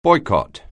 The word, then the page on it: boycott: anglais